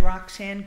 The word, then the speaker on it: Roxanne: Sure, my name is Roxanne Grunheid, and it's spelled R-O-X-A.